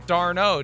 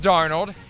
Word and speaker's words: Darnold: It just seems like yesterday, that 3 years ago, he was regarded as the top prospect in the 2018 draft, even though you continuously pronounced his name Darno. Do you remember those days? – I do, yes. – You eventually figured out it's Darnold.